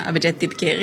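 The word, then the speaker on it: Abhijit Dipke: Ja, men det är alldeles korrekt att det är en rörelse och mer än ett, eller det är inget politiskt parti, och du uttalade dessutom Abhijit Dibke rätt här.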